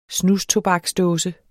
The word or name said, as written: snustobaksdåse